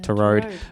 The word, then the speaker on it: Tureaud: I don't know how to pronounce that. T U R E A U D. Yeah, to road.